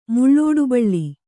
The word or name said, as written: ಮುಳ್ಳೋಡುಬಳ್ಳಿ